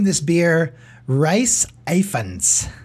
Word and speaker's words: Ifans: I-F-A-N-S. – Iphans.